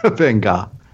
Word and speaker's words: Van Gogh: I love get my Van Gogh on. – Van Gogh.